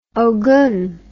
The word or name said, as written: ogum